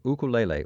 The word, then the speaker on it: ukulele: That's ukulele player Jake Shimabukuro, who's from Hawaii, and that was from his disc called Peace, Love, Ukulele, or ukulele if you prefer. I like the Hawaiian pronunciation.